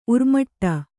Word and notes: ಉರ್ಮಟ: urmaṭa

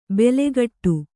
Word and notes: ಬೆಲೆಕಟ್ಟು: bele kaṭṭu

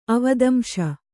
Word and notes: ಅವದಂಶ: avadamśa

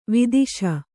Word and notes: ವಧಿಸು: kannada

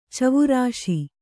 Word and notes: ಚವುರಿಸು: cavurisu

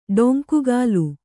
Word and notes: ಡೊಂಕುಗಾಲು: ḍoŋkugālu

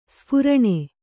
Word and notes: ಸ್ಫುರಣ: sphuraṇa

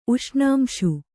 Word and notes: ಉಷ್ಣಾಂಶು: uṣṇāmśu

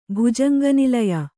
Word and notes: ಭುಜಂಗನಿಲಯ: bhujanga nilaya

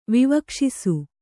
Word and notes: ವಿವಕ್ಷಿಸು: vivakṣisu

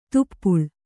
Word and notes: ತುಪ್ಪುಳ್: tuppuḷ

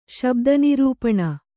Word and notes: ಶಬ್ದನಿರೂಪಣ: kannada

